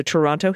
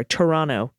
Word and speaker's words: Toronto: She was a rescue farm stray that found her way to the Toronto Humane Society. Sorry, Toronto Humane Society. I always pronounce that T and I always get emails for it.